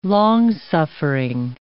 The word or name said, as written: long-suffering